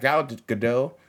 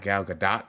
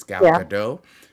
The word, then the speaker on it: Gal Gadot: It's got Gal Gadot, and apparently that's how you actually say her name. It's not Gal Gadot. It's Gal Gadot.